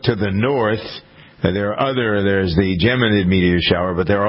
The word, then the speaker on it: Geminid: The is it Geminid? I think that's how Geminid. – You pronounce that.